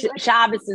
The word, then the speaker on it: Shabbos: Is it okay to say Shabbos? Am I saying that right? – Shabbos is good. You say it in the Ashkenaz way.